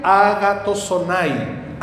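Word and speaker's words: agatosonai (ἀγαθωσύνη): Y se pronuncia agatosonai. Agatosonai. Dígalo conmigo. Agatosonai.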